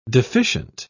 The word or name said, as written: deficient